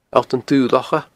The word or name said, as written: Allt an Dubh Locha